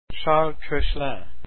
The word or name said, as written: Koechlin